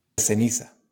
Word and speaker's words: ceniza: And sometimes the C is also pronounced th. So instead of ceniza, you'll hear ceniza.